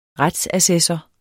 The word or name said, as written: retsassessor